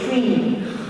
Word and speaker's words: cream: You don't say crema. – You say cream. – Come crema, vedi, se non si dice crema, si pronuncia cream.